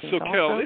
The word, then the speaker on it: Soquel: And then also you're going to be in Healdsburg on the 17th, and then down in near Santa Cruz on Soquel. Is that how you say it? On the 17th. – Yeah, Soquel.